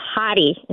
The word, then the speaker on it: Hodde: Is is it Hody or Hottie? – It's Hottie. – It's Hottie. German.